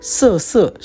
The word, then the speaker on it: 瑟瑟: 我们就来谈一谈呢。其中有一个东西叫做色色。啊，那个色就是我们现在讲的这个，呃，就是上面是两个王啊，下面一个碧，这个色啊，就是萧瑟，或是我们讲这个枫叶荻花秋瑟瑟的那个色，好，啊，上面两个王啊，下面一个碧字，好，这个这这个字念成色，好，那么这个呃色色是一种珠宝，在唐代呢是非常非常珍贵的。